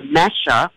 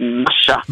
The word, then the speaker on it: Masha: When I was writing the book, I was saying in my very flat Australian accent, I was calling her Masha. – Mm-hmm. – But then Nicole Kidman, who has already bought the rights and who will hopefully play this role, she corrected my pronunciation with a beautiful Russian accent and said, no, it would be Masha.